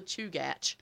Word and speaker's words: Chugach: But I did look up the Chugach, and it surprised me that's how it was pronounced. But Chugach, that's what it said.